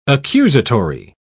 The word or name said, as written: accusatory